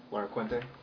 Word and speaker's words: Laracuente: Officer Julio, J-U-L-I-O. Last name is L-A-R-A-C-U-E-N-T-E. – And how do I say that? – Laraquente. – Laraquente. Okay, thank you. – Officer Raymond Pina. First name R-A-Y-M-O-N-D.